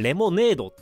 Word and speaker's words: Lemon8: このアプリの名前は、レモン 8。えっ、ー、と、英語で LEMON で、えっ、ー、と、数字の 8、えー、レモネードって読むらしいんですけれど、レモン 8 っていう黄色いアプリです。